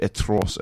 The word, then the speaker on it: atroce: And with Dimension, I believe, atroce, as it's pronounced in French, which is kind of a word that means like terrible, horrible.